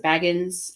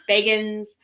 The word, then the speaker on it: Bagans: If you don't know who Zach Bagans is, please do not pronounce it Baggins, like Frodo Baggins. – The Lord of the Rings. – It's Bagans.